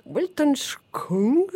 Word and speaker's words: Weltanschauung: Libitina avec le morceau Samskara de l'album Weltanschauung. – Tu prononces bien.